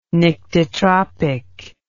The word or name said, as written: nyctitropic